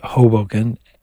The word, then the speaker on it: Hoboken: That's the way you pronounce it, Hoboken.